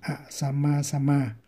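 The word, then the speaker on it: asamasama: Buddha là các chư Phật này, các chư Phật A Sama cộng với Sama thì đọc là A Sama Sama.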